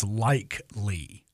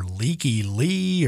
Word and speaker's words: Lykke Li: but I think I finally figured out that her name is Like Lee. And maybe that tells you just how really not smart I am, but her name is spelled L-Y-K-K-E-L-I.